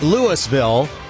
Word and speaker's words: Louisville: So finally, somebody got on there and said, hey, man, it's pronounced Louisville. And if you've listened to this show for any time at all, you know that that is the home of Bailey Zimmerman, who we're presenting August 14th at the Illinois State Fair. But we also pronounce it Louisville.